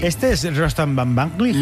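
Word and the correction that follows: Batmanglij: Casi bien pronunciado, ¿eh? O sea, tercero de Radio 3 tienes casi. – Van Banglig. – Batmanglig. – Batmanglig.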